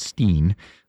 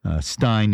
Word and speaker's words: Bernstein: I think I heard an interview where he pronounces it Steen, Stein in the original German, but Steen here.